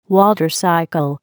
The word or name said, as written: water cycle